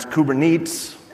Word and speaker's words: Kubernetes: That's Orca with a K, and it stands for orchestration with Kubernetes, which I was going to pronounce Kubernetes. And I checked, and they said Kubernetes, and I thought, you know what, I should have just pronounced it wrong.